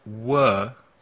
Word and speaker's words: were: Was, W-A-S, y were, W-E-R-E. I was, he was, she was, it was, we were, you were, they were. La pronunciación del were puede ser un poco difícil. El sonido vocal es Repite, were, we were.